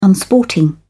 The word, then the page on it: unsporting: British English